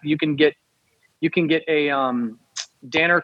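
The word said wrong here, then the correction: Döner: kebab. Uh, I'm mispronouncing it, but Donner kebab, D O N N E R kebab.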